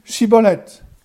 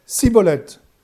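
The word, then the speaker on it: Schibboleth: Il lui disait alors Eh bien, dis Chibolette. Et il disait Cibolette, car il ne pouvait pas bien prononcer, sur quoi les hommes de Galade le saisissaient et l'égorgeaient près des guets du Jourdain.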